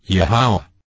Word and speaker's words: Yahweh: Lord was rendered from the Masoretic substitution of Adonai for YHWH, pronounced, Yahweh.